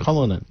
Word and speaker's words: Cullinan: But really, at the hand of an oil magnate, Joseph Cullinan, is that his? Cullinan. – Cullinan. – Yes. – My bad pronunciation.